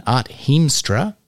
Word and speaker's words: Art Hiemstra: Art Hiemstra. Art, did I just butcher your pronunciation there? – Perfect. Couldn't have done better myself.